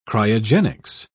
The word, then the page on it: cryogenics: British English